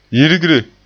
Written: yirgre

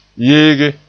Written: yeege

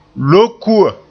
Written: lokua

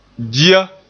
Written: dia

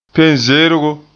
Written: penzeergo

